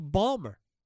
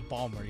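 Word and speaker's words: Baltimore: Yes, I love how you said Ballmer, yes. – It's how they say it. – It is how they say it. – Try to do it as the natives do.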